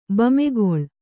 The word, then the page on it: ಬೆಮೆಗೊಳು: kannada